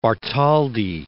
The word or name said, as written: Bartholdi